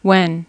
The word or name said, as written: when